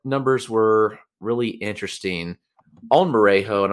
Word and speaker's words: Merejo: All right, I'm going to go to a little lesser known name, and that's Cleveland Guardians prospect Luis Marejo Jr., spelled M-E-R-E-J-O. So I hope I'm saying that right.